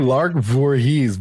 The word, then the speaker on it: Lark Voorhees: Are you saying it right, or are you supposed to say Lark Voorhees? – No, Lark Voorhees.